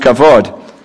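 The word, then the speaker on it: kavod (כבוד): And the more common word is a Hebrew word spelled K-A-B-O-D, which is pronounced kavod.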